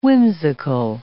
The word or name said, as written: whimsical